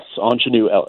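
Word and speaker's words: Aunjanue Ellis: How do you pronounce her name? – Which one are we talking about? – Anjanou Ellis. – Yes, Anjanou Ellis.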